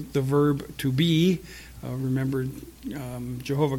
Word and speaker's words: Jehovah: Now, we don't, the Yod is often in English transcribed to a J. So you would have Jehovah. is the pronunciation.